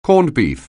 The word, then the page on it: corned beef: anglais